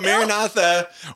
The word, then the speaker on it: Maranatha: So Maranatha was, am I saying it right? – Yes.